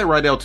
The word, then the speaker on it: Rydell: I say Rydell too. Some people are like, I'm on Riddle Road. – Yeah, I've heard Riddle. – I'm like, all right, I'm not going to argue with you because it's spelled like that, you know? – Excuse me. – Yeah. – That's hilarious. You know, it's all about where you put your emphasis on the syllable.